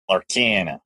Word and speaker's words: Lorcana: We're uh, about the Disney Locarna, Locarna, whatever. I don't know how it's said. – Never heard of it. – Uh, the card game. Yeah, There you go. Lorcana.